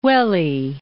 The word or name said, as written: Uele